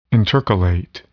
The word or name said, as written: intercalate